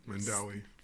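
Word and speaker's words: Mandaue: His wife, Debbie, moved to Mandu City. Am I saying that right? – No. – Mandawi. – Thank you, Mandawi City, in September of 2014, after a career as a youth pastor and youth ministry consultant in the United States.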